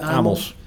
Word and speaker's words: Amels: En dat merk eh, wat ze daar voeren, dat heet Amos. – Spreek je dat ook zo uit? – Ja, Amos. Ja. Gewoon op zijn Nederlands.